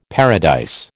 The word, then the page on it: paradise: American English